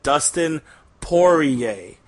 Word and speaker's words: Poirier: And he said, Dustin Poirier. So I think now you pour a beer and then go yay, because you're about to drink a beer.